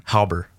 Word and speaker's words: Hauber: Hauber. – Hauber? – So, yeah. – Hauber? – It's almost like an O.